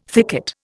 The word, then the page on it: thicket: Indian